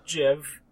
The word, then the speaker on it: GIF: It's supposed to be pronounced Jiv. – That having been said, other than that one dipshit... Do you guys actually know anyone who says GIF? – Yeah, my former roommate.